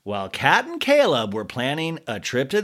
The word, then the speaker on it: Chleb: Caleb, remember, that's how you say his name. That's Catherine's boyfriend, or was Catherine's boyfriend. You spell it C-H-L-E-B, and I've translated that to Chubbs.